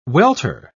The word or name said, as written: welter